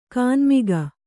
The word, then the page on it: ಗಣಮಗ: gaṇamaga